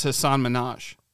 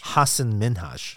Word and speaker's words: Hasan Minhaj: Well, I pronounced, I thought it was Hasan Minhaj. – It's Hasan. – Hasan. I had no idea. – Yeah, Hasan I thought it was Hasan and Minhaj. – It's Hasan Minhaj.